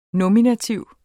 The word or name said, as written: nominativ